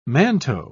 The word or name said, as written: manteau